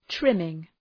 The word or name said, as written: trimming